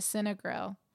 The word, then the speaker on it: Cinegrill: And one of the famous rooms they have, or the famous bars, is the Cinegrill. Am I saying it right? – Mm-hmm. – Okay, last time I said it wrong.